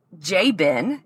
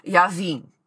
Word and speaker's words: Jabin: and his name in Hebrew is Yavin. Yavin.